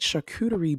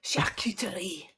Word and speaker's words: charcuterie: We will be experimenting with great charcuterie boards. – Charcuterie. You have to say it like the French.